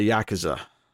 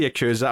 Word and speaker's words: Yakuza: dude is going to his boss in the Yakuza, right? Mm-hmm. I always say Yakuza. I'm trying to train myself to say Yakuza because it's actually the. – That's the actual pronunciation.